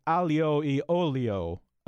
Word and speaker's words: aglio e olio: The first one, A-G-L-I-O, then the word E, then O-L-I-O. And I'm going to assume it's something like alio e olio. And I think it's probably something like garlic and oil. But please pronounce it for me. – Kathy, I'm going to let you do it. – Must we? – I panic. – Can't we just spell it again? You're so close, and I'm very impressed, because, again, the temptation is to go phonetic on it and say spaghetti aglio e olio.